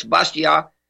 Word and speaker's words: Bastiat: Oh, I am appalled by your pronunciation, Monsieur Kelso, and I would like to quote Frédéric Bastiat. – Uh-oh. Yes, that's Bastiat. Frédéric Bastiat.